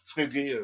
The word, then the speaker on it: Frege: Bon, je ne sais pas si je le dis comme en allemand. Frege, voilà, bon. Hein? On l'appelle Frege ou Frege en français. Ça s'écrit F-R-E-G, Frege.